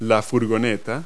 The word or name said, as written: van